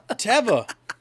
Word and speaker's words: Teva: Did you say Teva? – Teva. This motherfucker... Dude. – It's Teva.